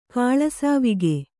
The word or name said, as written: ಕಾಳಸಾವಿಗೆ